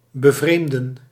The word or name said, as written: surprise